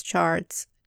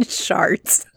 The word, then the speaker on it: charts: I just keep saying sharts. Because the TH and the CH are...